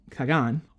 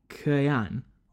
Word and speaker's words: Khagan: Whereas the second title, Khagan, or if we're really trying to pronounce it totally correctly, Khayan or Khan, means essentially Khan of Khans, or Great Khan, the overall ruler of a Khaganate.